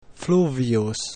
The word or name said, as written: fluvius